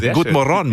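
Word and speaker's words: God morgon: Guten Morgon. – Gut Morgon, muss ich sagen. Okay, Betonung auf der letzten Silbe.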